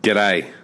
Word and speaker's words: G'day: How do you say it in Australia? G'day.